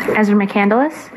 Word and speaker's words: Ezra McCandless: My name is Ezra McCandless. E-M-E-Z-R-A-M-C-C-A-N-D-L-E-S-S.